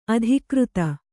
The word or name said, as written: ಅಧಿಕೃತ